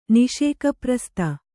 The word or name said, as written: ನಿಷೇಕಪ್ರಸ್ತ